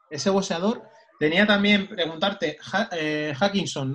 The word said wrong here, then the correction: Hutchinson: Willy Hutchinson.